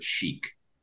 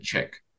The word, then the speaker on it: chic: And in sixth grade, I was reading in front of my class, and I mispronounced the word chic. I said chick.